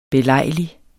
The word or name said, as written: belejlig